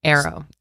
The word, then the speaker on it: AARO: Arrow. – Arrow, yes, that is the way it's pronounced. It's not double A-R-O.